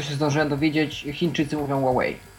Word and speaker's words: Huawei: Ja zawsze myślałem, że to się wymawia Huawei, tak z angielska, a to się okazuje, że to jest Huawei, tak?